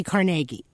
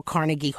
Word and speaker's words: Carnegie: So, of course, I had to put in both pronouncers because the hall does indeed call itself Carnegie, and that's yes.